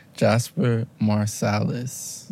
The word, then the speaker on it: Jasper Marsalis: Or, aka, how do I say your last name with an English accent? Jasper Marsalis. – Yes. – Correct pronunciation? – Correct. – Can you say it? – Jasper Marsalis. – Marsalis. Marsalis is not right.